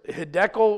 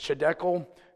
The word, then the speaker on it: Hiddekel: The name of the third is Hiddekel," or some people it's pronounced Chiddekel.